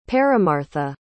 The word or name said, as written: paramartha